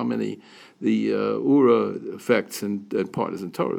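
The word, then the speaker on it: Oorah: So I offer to the two of you, to everybody here, these two organizations, Ura and Partners in Torah.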